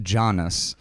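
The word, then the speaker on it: Jness: So I thought it was Janus for a while. It's J-NESS. – That's a Janus. – California. – It's pronounced Janus.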